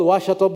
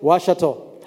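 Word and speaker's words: Ouachita: And I was in a university called Washita Baptist University. Now, it's not spelled like that. It's O-U-I-C-H-T-A. But you spell it Washita.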